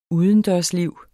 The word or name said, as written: udendørsliv